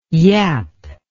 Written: yapp

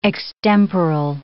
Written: extemporal

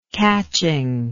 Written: catching

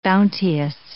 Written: bounteous

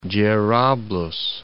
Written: Jerablus

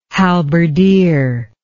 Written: halberdier

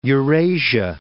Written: Eurasia

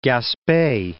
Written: Gaspe Peninsula

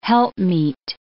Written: helpmeet

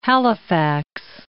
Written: Halifax

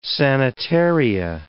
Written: sanitaria

